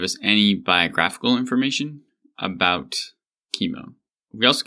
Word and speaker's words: Cimon: You might be tempted to pronounce it as Cimon, but that's wrong. It's spelled with a kappa in the Greek. So I am going to be insistent that it is Cimon for this episode.